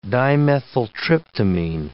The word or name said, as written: dimethyltryptamine